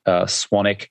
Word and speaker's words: Swanwick: My last name is spelled Swanwick, Swanwick, but it's actually pronounced Swanick.